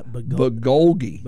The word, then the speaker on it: bulgogi: And then they have bulgogi. – Oh, yes. Good spicy bulgogi is some good stuff. – He got excited about that. – Yeah. I think y'all are speaking in tongues. – Yeah. Bulgogi.